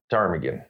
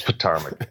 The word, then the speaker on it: Ptarmigan: I just love the, isn't there there's a silent P in there. That's fucking awesome. I only know it because the only reason I know how to pronounce that is because it's the name of a ski lift at Loveland Ski Area, I believe. – All right, teach me how to pronounce it. – Ptarmigan, I believe.